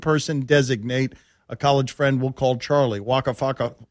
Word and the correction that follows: Waka Flocka Flame: waka flocka flame. – Waka flocka flame. – That's with an O.